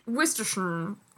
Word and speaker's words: Worcestershire: Worcestershire. – Great British accent. – Yeah, it's Worcestershire. – Worcestershire. Like the actual sound of the words has been withheld by the government. – Exactly. – Yeah. Worcestershire.